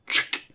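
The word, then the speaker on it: Ch'k: You guys always pronounce it wrong. It's chick. There's no vowel.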